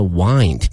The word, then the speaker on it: wind: 嗯，它其实它的原型呢是 wind，是风的那个拼写，但是呢它的意思和发音都完全发生了变化。它的发音呢是 wind，同时呢这个 wind up 表示说最后以什么样的结果而结局，就 wind up。